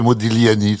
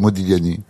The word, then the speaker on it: Modigliani: Vous dites quand vous voulez, mais on doit pas prononcer le G. – Non, c'est Modigliani.